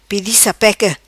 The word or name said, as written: Pedi Sapeca